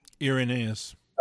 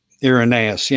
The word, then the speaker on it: Irenaeus: And I can't even give him exactly the credit, because he's only quoting Irenaeus, or Irenaeus. How do you say it? It's the right way to say that. – Irenaeus. – Irenaeus, yeah.